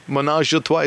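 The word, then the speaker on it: ménage à trois: Menage a trois, is that how you say it? – It's pretty on point.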